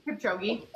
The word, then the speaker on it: Kipchoge: Kipchoge. – Kipchoge.